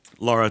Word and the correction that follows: Lara: Lara. How do you say it? L-A-R-A.